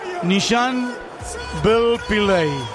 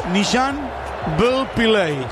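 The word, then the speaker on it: Nishan Velupillay: Sí, me ha llegado la pronunciación, Martín Leo. – A ver. – El profesor Nishan Belpilay. ¿Escuchaste? El delantero. Nishan Belpilay.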